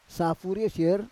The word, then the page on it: safur yosier: Roon